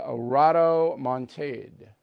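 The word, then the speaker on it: rodomontade: Do not be a rotomontade. Rotomontade. Huh? R-O-T, but it's pronounced rod. O-M-O-N-T-A-D-E, rotomontade, don't be that.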